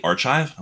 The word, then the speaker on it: archive: So in undergrad, I said the word that's spelled A-R-C-H-I-V-E, but I said it archive, and and the guys were like, did you just say archive? I was like, yeah, and they're like, it's archive, you fucking idiot.